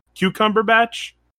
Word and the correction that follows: Cumberbatch: Cumberbatch. Yeah.